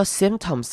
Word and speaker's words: symptoms: Scientific symptoms，好，看到 symptoms 这个字，中间这个开头这个 y 要念成短母音 i 的声音，symptoms。